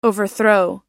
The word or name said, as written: overthrow